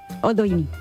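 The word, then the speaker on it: Oldoini: Virginia Oldioni, la condesa de... ¿Oldioni? ¿Lo he dicho bien? – Oldioni. – Ah, perdona. No, está bien que me corrijas.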